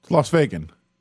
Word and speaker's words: Las Vegan: I guess I am officially Las Vegan? Vegan? How do I say that, even? I guess I am official, though. – Las Vegan. – Las Vegan. Okay, there it is.